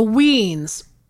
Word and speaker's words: 'Weens: The weens. I the way it's spelled, apostrophe W-E-E-N-S... is like a Halloween. It's like the last half of a Halloween.